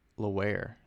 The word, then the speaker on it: Laurent: Wait, did they spell it? Oh, it is Laware. It's Laware. I'm sorry. I thought it was Lawrence and they misspelled it on something I saw.